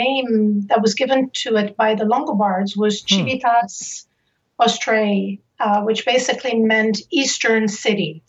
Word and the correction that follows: Civitas Austriae: I'm going to say it again. It's Civitas Austriae, which is Latin.